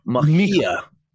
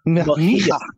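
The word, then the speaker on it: Mejía: I believe the J in Spanish is an H. Mejia. Mejia.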